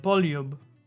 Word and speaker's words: Poljub: Remember, J sounds like a Y. Poljub.